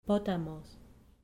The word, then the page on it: potamos: grec